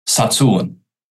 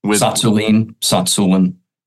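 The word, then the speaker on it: Tsatsouline: And just for our listeners, just in case, how do you say Pavel's last name? – Satsulin. – Okay. – Satsulin. – It starts with a silent T, but... – Yes. – Goes downhill from there.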